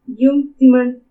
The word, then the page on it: Iumu tsimani: Purépecha